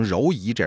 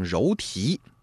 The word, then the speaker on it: 柔荑: 那么手如柔荑，哎，柔荑，那很多人呢把它念成柔仪，这是不对的啊，应该念成柔荑。